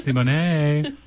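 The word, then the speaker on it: Symoné: It's pronounced Simone.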